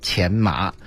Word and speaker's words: 荨麻: 其实大家对那个字儿吧，不用太纠结啊，那个字儿，是表示那个药的时候念前麻，然后如果是这个麻疹的时候就念荨麻疹，